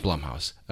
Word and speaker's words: Blumhouse: Blumhouse.